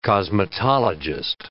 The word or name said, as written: cosmetologist